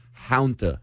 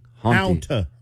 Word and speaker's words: Hanta: Please pronounce it correctly. Hanta, like a haunting. – Hanta. – Hanta. – I guess just pretend there's a U in there.